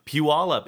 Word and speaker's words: Puyallup: Puyallup.